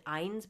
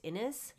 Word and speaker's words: Innes: I mean, I always pronounced it Innes, but I think it's Innes.